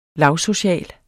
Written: lavsocial

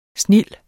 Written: snild

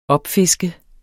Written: opfiske

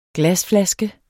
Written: glasflaske

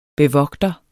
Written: bevogter

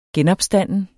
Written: genopstanden

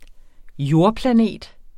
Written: jordplanet

